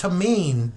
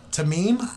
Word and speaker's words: Tamim: Did I say the last name right? – You did not, but I appreciate the effort. – How do you say the last name? – Tamim. – Tamim? – Tamim, you got it.